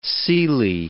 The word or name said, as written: seely